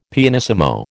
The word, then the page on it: pianissimo: American English